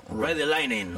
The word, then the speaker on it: Ride the Lightning: Pero realmente descubro el mundo metálico con el Ride the Lightning de Metallica. Así se pronuncia, ¿no? – Ride the Lightning.